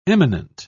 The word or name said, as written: eminent